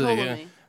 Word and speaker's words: Ptolemy: He was Ptolemy. – And the funny thing about Ptolemy is he's the only one that would pronounce the P.